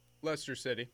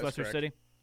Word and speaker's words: Leicester: and I know they pronounce the name different than how it looks. I think is it Leicester City? I'm not into soccer. – Leicester City. – Yeah, it is Leicester City.